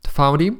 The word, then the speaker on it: found.ee: Foundy, which is spelled as a web address at found.ee, is a free platform that allows anyone in the music industry to generate retargeting pools consisting of interested fans through clicks.